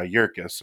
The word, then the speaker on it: Yerkes: But I think one thing that's worth talking about, you had mentioned the name Charles uh, Yerkes. Am I saying that right? Right. Yes, so Charles.